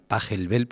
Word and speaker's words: Pachelbel: Todos los que lo habéis escuchado sabéis el nombre, que es el canon, y el autor, que es Pachelbel. Bueno, los puristas dirían que habría que pronunciar Pachelbel, pero nos vale cualquiera de las dos cosas.